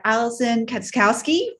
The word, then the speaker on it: Katschkowsky: I am joined today by Alison Katschkowsky from CU Fitness.